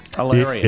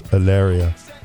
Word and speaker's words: Hilaria: Alaria. – Alaria? – Yeah, it looks like Hilaria. – Or Malaria. – Hilaria. But there's an H silent? – The H is silent, gotcha. Yes. Alaria.